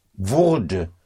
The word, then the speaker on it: wurde: To make that sound distinction between würde for would and wurde for was getting, was becoming, was getting.